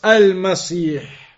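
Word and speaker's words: المسيح: It's a mistake to give those other pronunciations. It is just Al-Masih for both. Al-Masih, Al-Dajjal, and Al-Masih, Isa alayhi salam.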